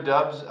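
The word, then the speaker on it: Doubs: It's Romeo Dubs. – Romeo Dubs. – Romeo Dubs there. – Romeo Dubs. Okay, so it is pronounced how I thought it was. The O is throwing me off there.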